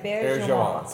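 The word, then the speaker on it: Auberjonois: Yeah, so it's, was it Rene Abergenois? – Abergenois. – There we go. – That's the way he pronounced it. Abergenois.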